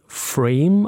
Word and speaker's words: Ephraim: Geschrieben wird es so wie Ephraim, ein hebräisches Wort, das für Fruchtbarkeit steht, auch ein biblischer Begriff. Aber ausgesprochen wird es wie Frame, also wie der Rahmen auf Englisch.